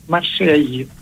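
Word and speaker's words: Marseille: Στα γαλλικά προφέρεται Μασέγι. Ακούγεται το γη, τέλος πάντων. – Ναι, και δεν ακούει, δεν προφέρεται το τελευταίο γράμμα, αλλά...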